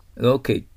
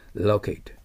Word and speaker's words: locate: ね、 locate という動詞ですね。頭に来ているのは米国発音ですね。 locate。で、 locate とも発音できます。両方あります。